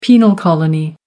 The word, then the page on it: penal colony: American English